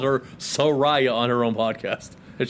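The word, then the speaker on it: Saraya: Soraya. People say it right. It's Soraya.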